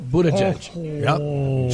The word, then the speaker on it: Buttigieg: Did I say his name right, or is it Buttigieg? – No, Buttigieg. – Judge a butt? Yep. Backwards? – Judge a butt, yeah.